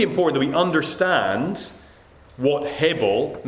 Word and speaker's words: hebel: I'm going to say it like that, hebel.